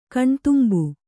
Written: ಕಣ್ತುಂಬು

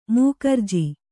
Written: ಮೂಕರ್ಜಿ

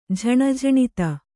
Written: ಜನಜನಿತ

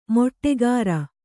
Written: ಮಾಟಗಾರ

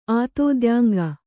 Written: ಆತೋದ್ಯಾಂಗ

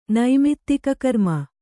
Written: ನೈಮಿತ್ತಿಕಕರ್ಮ

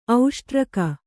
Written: ಔಷ್ಟ್ರಕ

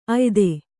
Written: ಅಯ್ದು

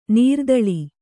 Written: ನೆರೆದಲೆ

